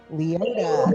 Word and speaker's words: Leota: The new Madame Leota, how do you say her name? – Leota. – Leota.